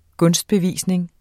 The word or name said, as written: GUNSTBEVISNINGERNE